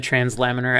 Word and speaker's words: translaminar: Is that powder form going to act the same way as the liquid form when it comes to the transluminar, is that how you say it? – Oh, the translaminar activity? – Laminar, yeah.